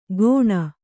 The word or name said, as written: ಘೋಣ